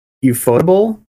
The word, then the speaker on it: Ufotable: Because I believe Ufotable is that how you pronounce it? – Yeah.